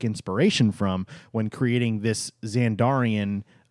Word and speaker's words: Xandarian: And one of those first things is going to be the Xandarian Progress City. Did I say that right? – Xandarian. – Dang it.